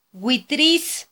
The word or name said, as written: witris